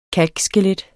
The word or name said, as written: kalkskelet